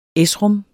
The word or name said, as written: esrom